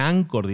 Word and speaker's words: Anchor: Se pronuncia Anchor, se escribe Anchor, es un ancla, digamos.